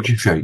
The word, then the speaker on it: Giuffre: the claims of victoria um Giuffre am i pronouncing her name correctly virginia Giuffre yeah sorry